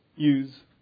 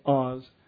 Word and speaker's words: Uz: And so in Job chapter 1 it says this: there was a man in the land of, I say, Uz, because I pronounce the U out loud. If it's an O, it's O's, because I'm not going to say Oz.